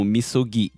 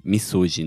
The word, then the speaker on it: misogi: Aí, esse ato cara dele toma banho no rio é conhecido como misogi, misogi, né, na pronúncia em português.